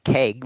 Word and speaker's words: CEG: I'm going to call it Keg with a hard C.